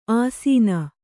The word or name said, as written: ಆಸೀನ